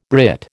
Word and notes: Brit: American English

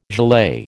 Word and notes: gilet: American English

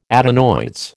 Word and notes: adenoids: American English

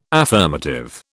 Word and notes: affirmative: British English

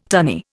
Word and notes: tunny: Indian

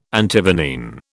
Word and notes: Antivenene: British English

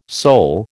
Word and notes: Sol: American English

